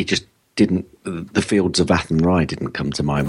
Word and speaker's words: Athenry: And by the way, you pronounce it Athenry if Ken Athenry.